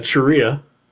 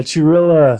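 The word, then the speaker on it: Cirilla: So according to the internet here, Brian Sharia. – That's how you pronounce it? – Is not how you pronounce it. – Oh. – It, rhymes with gorilla. – Yeah, Chirilla.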